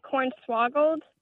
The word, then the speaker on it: cornswaggled: C-O-R-N-S-W-A-G-G-L-E-D. – Interesting. Are you saying corn swaggle or corn swoggled? – We say it like corn swoggled.